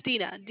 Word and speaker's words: Dina: It's Dina.